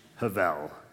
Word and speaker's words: hebel: Thirdly, I want you to understand a very important term in this book, which is pronounced Hebel. It's written Hebel, H-E-B-E-L.